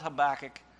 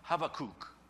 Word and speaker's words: Habakkuk: Because in Hebrew, it's this CH sound that's kind of a guttural sound that we don't really make in English. So we English speakers would say it wrong anyway. It would be something like Habakkuk.